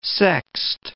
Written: sext

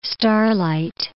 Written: starlight

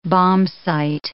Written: bombsight

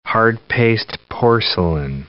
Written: hard-paste porcelain

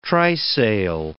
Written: trysail

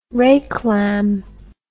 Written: reclam

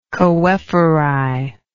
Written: choephori

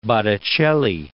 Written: Botticelli

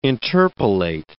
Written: interpolate

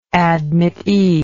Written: admittee

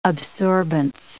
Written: absorbance

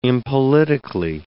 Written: impolitically

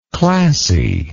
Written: classiness

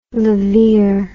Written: laveer

